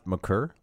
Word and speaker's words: Maker: Okay, there's also Thon Maker, mm-hmm. who at 7'1" is. – I believe it's pronounced McCurr, but okay.